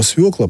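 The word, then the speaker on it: свёкла: Да, конечно, слово «свекла» пока что сохраняется только так. Я думаю, что здесь не будет изменения, потому что это слово через «ё» пишется вообще-то не через «е», да, а через «ё». И на букву «ё», так сказать, она всегда обозначает ударный звук у нас в русском языке.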